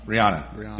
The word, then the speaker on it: Rihanna: Like Rihanna says, Rhiannon. What was it? Rhiannon? Did I say that wrong? – Rihanna. Yeah, like the singer.